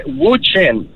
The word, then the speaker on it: Wu Chen: Wu Chen, es W-U-C-H-E-N.